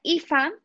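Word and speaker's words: Aoife: Uno que dije, oh my god, es, se pronuncia IFA, pero se escribe AUIFE.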